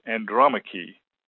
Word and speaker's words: Andromache: So, for example, you see Agamemnon with its accent on the third syllable, but you also see Andromache with the accent on the second syllable.